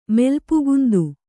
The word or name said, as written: ಮೆಲ್ಪುಗುಂದು